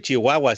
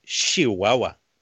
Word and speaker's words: Chihuahua: Y no se dice Chihuahua, se dice Chihuahua, porque así lo pronuncian allá en Chihuahua.